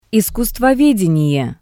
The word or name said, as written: iskusstvovedenie